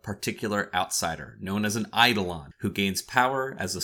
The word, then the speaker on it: Eidolon: It's Eidolon. Eidolon. That's how you say it. – Yeah, I say Eidolon or Eidolon, so you can huff my shorts. – No, according to Greek literature, it's Eidolon. That's how you say the word.